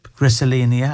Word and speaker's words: griselinia: But how far should they set mature griselinia? Am I saying it right? – Yeah.